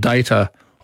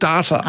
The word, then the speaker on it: Data: There was an android in it called Data. Or was it Data? – Ah. Yes, Data sounds very American, doesn't it? And sounds more modern. But in fact, that was around about the same time. In fact, in my Daniel Jones pronouncing dictionary from the 1800s, he recommends Data.